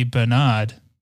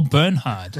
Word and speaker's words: Bernhard: He's just a guy um, who who was a born again Christian who was friends with a film producer named Harvey Bernard or Bernhard, depending on your pronunciation. He's back. Um, spelled Bernhard.